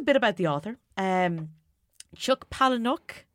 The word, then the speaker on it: Palahniuk: Fight Club is a 1996 novel, and I'm going to butcher this guy's name, by Chuck Palahniuk? – Palahniuk.